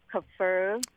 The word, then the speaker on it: kefir: It's pronounced kefir. – Yes, it is.